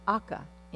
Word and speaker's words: Auca: And the group that he was focused on was called the Alca, but it's spelled A-U-C-A, Indians.